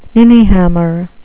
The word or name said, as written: ninnyhammer